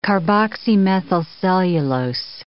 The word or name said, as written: carboxymethylcellulose